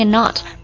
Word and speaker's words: knot: 结婚呢还有一个表达 tie the knot，要注意一下这个 knot K 不发音 N-O-T tie the knot。